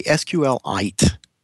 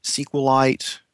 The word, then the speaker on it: SQLite: I say SQLite, like a mineral. – Okay. – But I also hear a lot of people say SQLite and SQLite.